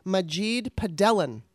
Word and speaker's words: Majid Padelan: And your real name, and I'm going to hopefully say this right, is Majid Padelan.